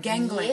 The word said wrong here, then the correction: galangal: gang, what? Yeah, how do you say that one? that one? You know the one I'm talking about. Uh, galangal. Galangal. Thank you.